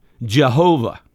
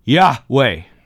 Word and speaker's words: Yahweh: So those four consonants in Hebrew, nobody knows how to pronounce it. About 200 years ago or so, German theologians decided to add their vowels, and they came up with, guess which word? Jehovah. Well, that was a German attempt. And the Jehovah is often included in a lot of our Bibles. Today, most scholars believe that those four letters would be pronounced, here you go, Yahweh.